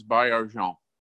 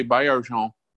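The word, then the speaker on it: Baillargeon: When you change in your pronunciation guide, for example, my last name is Bayarjon, and it's French-Canadian, so I had to spell it phonetically to change it. Of course, Siri, when you have it programmed for English, if you say Bayarjon, or even if you say it the English way, by Argent, it doesn't get it.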